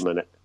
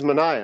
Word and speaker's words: Manaea: It's Sean Manaea. – It is Manaea, and I would have mispronounced it, so thank you for saving me.